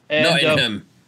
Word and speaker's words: Nottingham: Nottingham. – And yeah. – Yeah, you can't pronounce the A that much.